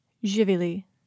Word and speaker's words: živili: You say, živili.